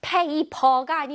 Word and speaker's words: paper: paper가 아니고 paper. 자, 입에서 바람이 슝 나온다고 생각하고 해볼게요.